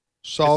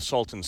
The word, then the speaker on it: Salton: S-A-L. – Salton. – Spell it. – S-A-L-T-O-N. Salton. It's not Salton.